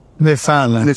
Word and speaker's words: Newfoundland: Anyway, he lands on this little island near Newfoundland. That's how the locals pronounce it. – Newfoundland.